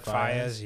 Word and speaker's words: Faiyaz: Brent Faiyaz.